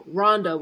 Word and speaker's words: Rhonda: Yes, this is Rhonda. Oh, hi, Wanda. And I'm like, do I really sound like I'm saying W? Like, no, Rhonda with an R.